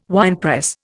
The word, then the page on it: winepress: Indian